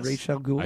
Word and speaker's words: Ra's al Ghul: Is that how that's pronounced? – Yes. – Ra's al Ghul? – I found that out. – Raj? – How did Raj? – You find that out? – Well, first, because one of the writers of the Batman comic complained about it. He's like, people have been saying it wrong for years.